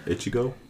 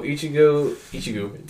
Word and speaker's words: Ichigo: Ichigo. – So Ichigo. Damn, I even said his name wrong.